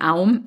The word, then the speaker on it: Aum: Because the first sound in Aum is A, right? So produced in the back of the throat at the mouth first.